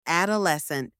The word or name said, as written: adolescent